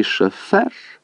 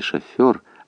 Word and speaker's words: шофер: «После адмирала и шофер?» Она произносила это слово по принятой тогда манере, не «шофер», а «шофер» с сильным ударением на втором слоге.